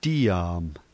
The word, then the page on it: tiam: slow